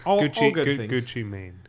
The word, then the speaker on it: Gucci Mane: Like it's bouncy, it's peppy, it's 100% Gucci Man free. So Gucci Mane. – Gucci Mane? – Yeah.